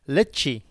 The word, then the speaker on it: Ledaig: Lecce. – Lecce, even though it's spelt with a D, LEDAIG, but it's Lecce. – So I was under the impression that it was called the Ledaig, and I've been calling it that for the last 6 months since it's been in my cupboard along with the other whiskies.